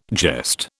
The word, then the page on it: jest: British English